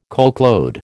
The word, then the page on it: coachload: American English